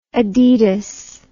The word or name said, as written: adidas